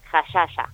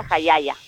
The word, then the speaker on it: jallalla: Sí, se escribe J-A-L-L-A-L-L-A, jayaya, y se pronuncia jayaya.